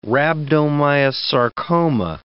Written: rhabdomyosarcoma

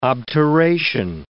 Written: obturation

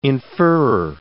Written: inferrer

